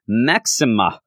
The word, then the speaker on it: Maxima: We do start off, you know, in the whole Blackstone deal with Sebastian Shaw talking to Manon and Maxima. is what I have found out is the pronunciation.